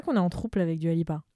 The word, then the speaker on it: Dua Lipa: Je l'ai bien prononcé, ça va? – C'est Dua Lipa.